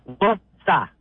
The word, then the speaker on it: Worcester: Worcester. Yeah, Two syllables. Worcester.